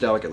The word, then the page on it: delicately: American English